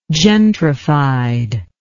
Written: gentrified

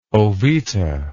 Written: oveta

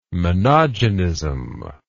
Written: monogenism

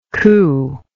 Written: cooingly

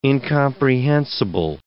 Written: incomprehensible

